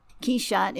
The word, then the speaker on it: Quixote: Quixote is the French pronunciation, we're told by Mr. Rushdie.